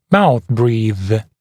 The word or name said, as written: mouthbreathe